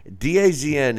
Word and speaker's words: DAZN: Yeah, they for some reason they pronounce it DAZN, is the way that the app is pronounced, is DAZN.